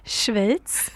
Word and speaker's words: Schweiz: Schweiz.